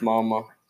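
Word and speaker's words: Mma: Och det stavas med dubbel M sen A. Men det uttalas mamma.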